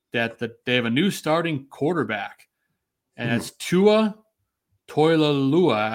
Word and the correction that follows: Tagovailoa: I can't pronounce it the right way, Tooch. – Tua Tagovailoa.